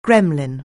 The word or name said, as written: gremlin